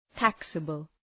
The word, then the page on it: taxable: ‘tæksəbəl